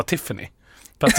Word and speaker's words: Tiphaine: Tiffany, det måste vara Tiffany. Fast det stavas Tiphaine. Tiphaine.